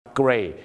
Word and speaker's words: gray: gray อย่าลืมนะครับคำว่า gray เราก็เคยพูดมาบ้างแล้วนะครับว่า gray นั้นสะกดกัน 2 แบบคือ grey กับ gray แล้วต้องออกเสียงรอเรือชัดเจนไม่งั้นจะกลายเป็นเกย์ก็แปลว่ากระเทย